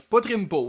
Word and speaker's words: Potrimpo: Et on a ultimement le ciel d'urne qui est rempli par le dieu Potrimpo. Je le prononce tout croche. Potrimpo, oui, c'est ça.